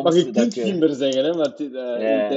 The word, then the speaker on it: GIMBER: Ik zeg altijd GIMBER. Dat is duidelijk fout, want ik hoor u iets anders zeggen dan GIMBER qua uitspraak. – GIMBER. Ja, GIMBER. Ja, maar, dus zelf in het Nederlands moet je eigenlijk GIMBER zeggen.